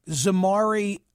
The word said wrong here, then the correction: Zemari: Ahmadi. I hope I'm doing right by the pronunciation of the engineer's name. – Zemari, but yeah.